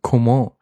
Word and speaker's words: Coman: Und endlich wird Kingsley Coman beziehungsweise Coman richtig ausgesprochen.